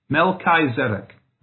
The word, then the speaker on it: Melchizedek: Melchizedek is actually how you would pronounce it more closely.